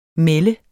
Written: MELDTES

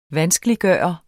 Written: VANSKELIGGJORT

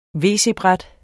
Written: wc-bræt

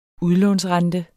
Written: udlånsrente

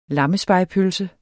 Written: lammespegepølse

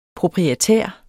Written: proprietær

